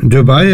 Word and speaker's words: Dubai: Det er du vei, nå vet ikke om jeg uttaler det riktig. – Ja. – Er det riktig? – Dubai er riktig.